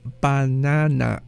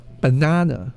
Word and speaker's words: banana: Or the word banana is not banana. It's the first and last A, the first and last A are schwa. It's banana. The takeaway here is when you are practicing your speaking skills, stop trying to pronounce vowels the way they are spelled.